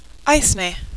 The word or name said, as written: eithne